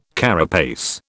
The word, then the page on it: carapace: British English